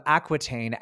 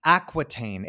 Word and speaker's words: Aquitaine: Okay, so how do I say it again? Eleanor of Aquitaine. – Aquitaine. It sounds like smart water.